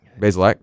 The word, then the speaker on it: Bazelak: Bazelak, right? So I'm going to pronounce that name. That's correct.